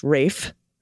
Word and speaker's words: Ralph: and Ralph, pronounced Rafe Fiennes, a.k.a.